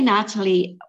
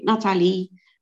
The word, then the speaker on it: Nathalie: You can say Nathalie. – Nathalie? – It's, yeah, well, we say Nathalie, but that's because it's French, but I don't mind Nathalie.